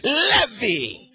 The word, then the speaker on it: Levi: The I in Hebrew is not a long I, it's a short I. Levi. The Lord is my shepherd, Rohi, R-O-H-I.